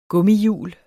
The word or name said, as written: gummihjul